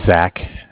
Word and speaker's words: Zach: So my name is Zach, spelled Z-A-C-H, mm-hmm.